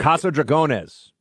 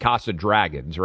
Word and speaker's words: Casa Dragones: Casa Dragones. – Dragones. Right. I would basically pronounce it Casa Dragons, right? Dragones, but it sounds cool either way.